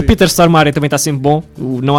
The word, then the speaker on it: Stormare: Sim, é a verdadeira pronunciação de Stormare. – Stormare, em sueco.